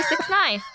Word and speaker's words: 6ix9ine: Yeah, 6ix9ine. – 6ix9ine? There's so many more letters and numbers in his name. – I mean, I just know that's how you say it.